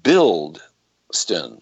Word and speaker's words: Bilston: It's pronounced Bilston. – Yes. – As in building, and you're an architect.